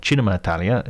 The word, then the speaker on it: Cinema Italia: It's called Cinema Italia, or Cinema Italia, if I give it the proper Italian pronunciation,